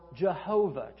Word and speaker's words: Jehovah: Anytime you see that in your Bible, that is the Hebrew word Yahweh or Jehovah. Jehovah is just a more Latin pronunciation, but Yahweh. In Hebrew, there are no vowels, and so you'll often see it just Y-H-W-H.